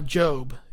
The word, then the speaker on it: Gob: And he plays this guy, Job. It's spelled G-O-B, but its name is Job.